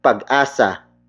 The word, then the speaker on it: pag-asa: It is the focus marker, indicating that the next word is the focus of this sentence, and that is pag-asa. Pag-asa. Pag-asa. Pag-asa is spelled as P-A-G hyphen A-S-A.